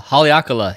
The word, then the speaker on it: Haleakala: Hanalea, Hakalea? Haleakala. Haleakala.